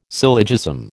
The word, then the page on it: syllogism: American English